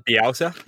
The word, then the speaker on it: Bielsa: Is it Bielsa? – Bielsa, yeah.